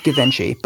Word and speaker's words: Givenchy: It's pronounced Givenchy, but go ahead.